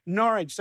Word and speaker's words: Norwich: I think that's how Americans pronounce it. But we say Norwich, so it sort of rhymes with porridge.